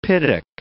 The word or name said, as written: piddock